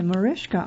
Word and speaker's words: Mariska: Okay, Mariska, I believe, is the way this name is pronounced. It's M-A-R-I-S-K-A. The last name, H-A-R-G-I-T-A-Y. And Jane, spelled J-A-Y-N-E, Mansfield.